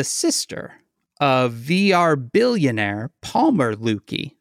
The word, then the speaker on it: Luckey: Her name is Ginger Lukey. I think that's how you pronounce it. L-U-C-K-E-Y. – When maybe you say it's lucky lady, it's Lukey. – It does actually look like Lukey.